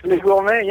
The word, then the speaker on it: Les Gourmets: So, how have the Les Gourmets, am I pronouncing this correctly, Les Gourmets? – It's perfect, Les Gourmets, yes, very good.